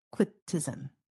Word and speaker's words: Equitism: I never saw that before. Equitism. I don't know if I'm pronouncing it right. Yes.